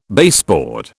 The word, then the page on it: baseboard: British English